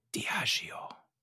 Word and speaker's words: Diageo: Do you want to know how it's actually pronounced, Shale? – Yeah. – It's pronounced Diageo.